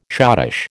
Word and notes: schottische: American English

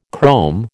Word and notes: chrome: American English

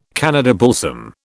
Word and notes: Canada balsam: British English

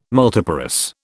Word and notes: multiparous: British English

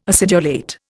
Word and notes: acidulate: Indian

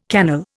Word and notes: cannel: Indian

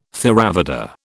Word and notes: Theravada: British English

